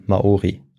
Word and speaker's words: Maori: Und der große Unterschied zu den, äh, zur Bevölkerung, zu den Einheimischen, den Indigenen auf Neuseeland, ähm, ist, dass eben dort noch ein R drin ist, wie du es richtig gesagt hast, ja. und die eben Maori ah, genannt ah, werden.